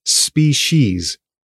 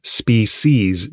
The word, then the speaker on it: species: Another note before we dive in, you can hear that I pronounce the word as species, As I understand it, that's the earlier and arguably more correct pronunciation. But just as birds evolve, so too does language. I hear a lot of people saying species nowadays. So it's all good. Either pronunciation is fine.